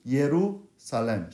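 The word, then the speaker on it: Yerusalem (ירושלים): Yeru, actually in Hebrew, pronounces a Yeru. Yerusalem. Shalem. Shalem.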